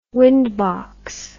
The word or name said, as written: windbox